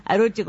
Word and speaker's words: Aroche: Se llama Sergio Aroche, lo digo porque si no después me calla con mi pronunciación francesa, Aroche con H, y va a estar aquí un premio Nobel en física, cosa que me parece extremadamente estimulante.